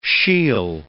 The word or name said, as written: shiel